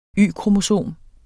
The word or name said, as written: Y-kromosom